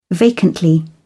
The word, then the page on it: vacantly: British English